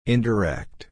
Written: indirect

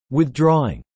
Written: withdrawing

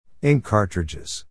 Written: ink cartridges

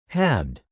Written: halved

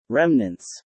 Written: remnants

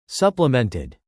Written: supplemented

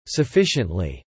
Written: sufficiently

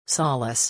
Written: solace

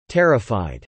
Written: terrified

